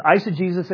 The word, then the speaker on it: eisegesis: And I should have had a slide up there, but if you're taking notes, there's two different ways, eisegesis and exegesis. Eisegesis, it's spelled E-I-S-E-G-E-S-I-S. Okay?